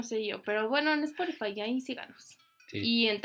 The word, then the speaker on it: Spotify: Eh, denos like, este, sigan también en, síganos también en Spotify, ¿sí lo dije bien? – Sí, Spotify, en...